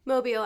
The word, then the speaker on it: Mobile: Mobile, Alabama.